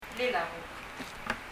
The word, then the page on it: blil a buk: blil ə buk